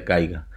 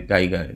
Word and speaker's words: Geiger: Gaiga. Gaiga se escribe G-E-I. A ver, ahorita les digo cómo se escribe Gaiga. G-E-I-G-E-R.